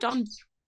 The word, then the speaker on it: Don Broco: Don Broco? Don Broco. I feel like that's the only way to pronounce it.